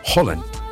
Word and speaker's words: Haaland: Y la doble A es Haaland.